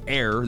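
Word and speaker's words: Aire: It's spelled with an E at the end of it, but pronounced Air.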